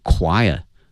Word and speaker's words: choir: 这个演出呢它的一个意义呢在于 featuring 哈，它呢是庆祝 the Shanghai Disabled People Choir 啊，那么说上海残疾人合唱团，这个合唱团它的拼写是 C-H-O-I-R，它的发音是 choir， choir 啊，它不是按那个 C-H 啊传统的一个发音的方式来念啊，它是发 k 这个音哈 choir。